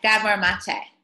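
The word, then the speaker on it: Gabor Maté: Gabor Mate.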